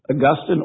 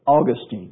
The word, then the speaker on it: Augustine: Augustine or Augustine, depending on which syllable you put the accent.